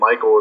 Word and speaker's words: Mychal: and Mychal is spelled M Y C H A L.